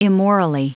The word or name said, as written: immorally